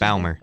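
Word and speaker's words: Bäumer: In October 1918, a month before the end of World War I, Paul Baumer, is that how you pronounce Baumer.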